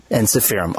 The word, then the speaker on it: Ensiferum: Is it Ensiferum? Ensiferum? How do you prefer to pronounce it? – We pronounce it Ensiferum. – Ensiferum.